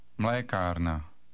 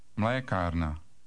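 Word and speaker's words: mlékárna: In number 16, don't forget to put the stress on the first syllable. – Mlekarna. – Read number 16 again. – Mlekarna.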